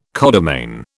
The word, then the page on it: codomain: British English